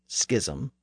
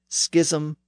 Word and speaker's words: schism: Now, I said schism, but you may pronounce that word differently. The Oxford English Dictionary says that schism, schism, and schism are all acceptable pronunciations.